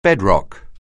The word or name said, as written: bedrock